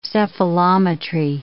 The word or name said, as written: cephalometry